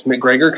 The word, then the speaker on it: McGregor: Just search Thomas McGregor, kind of like Connor McGregor, and I'm not allowed to say if I'm related or not, but same last name spelled the same way.